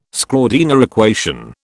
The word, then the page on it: Schrödinger equation: British English